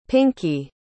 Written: pinkey

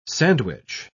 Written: sandwich

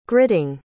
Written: gritting

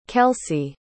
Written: kelcey

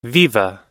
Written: viva

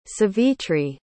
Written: savitri

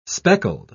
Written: speckled

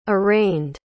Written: arraigned

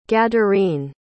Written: gadarene

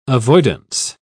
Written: avoidance

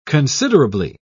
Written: considerably

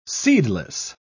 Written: seedless

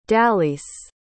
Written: dallyce

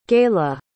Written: gayla

